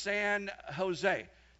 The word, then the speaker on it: San Jose: You don't pronounce the J here. It's pronounced San Jose.